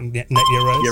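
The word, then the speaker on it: Net Yaroze: I can never pronounce this right, but is it the Netyaroos or Yaros? – Yep, correct. Netyaroos.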